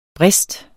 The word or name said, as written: brist